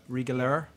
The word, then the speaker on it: Riegeler: There's something called Riegeler, not Riegele, Riegeler. – R-I-E-G-E-L-E? – E-R.